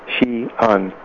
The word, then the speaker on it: Xi'an: You pronounce it like S-H-E, Xi'an.